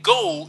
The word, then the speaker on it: geaux: Ordinarily, if one did not see it written down on paper, one would assume you are saying on the go as in O-N-T-H-E-G-O. But the go is spelled G-E-A-U-X.